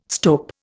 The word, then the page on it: stope: Indian